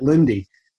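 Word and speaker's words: Linde: Actually, is how you say it, Lindy.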